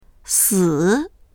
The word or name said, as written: si3